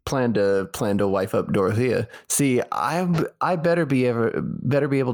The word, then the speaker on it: Dorothea: Dorothea? – Yeah. – Yeah, it looks like Dorothy, but it's Dorothea is how they pronounce Dorothea.